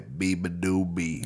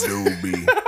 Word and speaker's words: Beabadoobee: Beatopia by Beabadoobee. – There it is. – Beabadoobee.